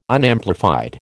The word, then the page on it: Unamplified: American English